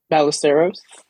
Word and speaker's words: Ballesteros: Am I saying that right? – Ballesteros. – Ballesteros. I almost got that.